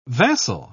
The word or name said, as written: vassal